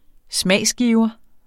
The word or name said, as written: smagsgiver